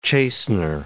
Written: chastener

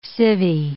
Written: civvy

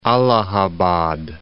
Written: Allahabad

